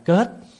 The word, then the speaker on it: kiết: Chữ kiết á còn được đọc là kết. Kết hay kiết là cùng một chữ.